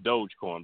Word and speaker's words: Dogecoin: So, yeah, it's pronounced Dogecoin, by the way.